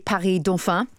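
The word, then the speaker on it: Paris-Dauphine: The city's Université Paris-Dauphin, did you like that pronunciation? – That was very good.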